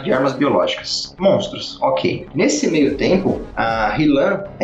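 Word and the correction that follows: Nilin: É Hilan? Falei certo o nome dela? – Nilan.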